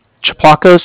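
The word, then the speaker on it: Tsiplakos: Chaplakos,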